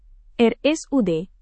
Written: RSUD

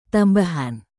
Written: tambahan

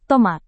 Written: tomat

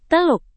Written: teluk

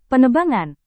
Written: penebangan

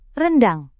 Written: rendang

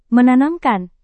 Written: menanamkan

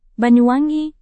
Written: banyuwangi

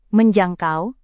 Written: menjangkau

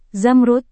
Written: zamrud

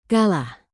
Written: kalah

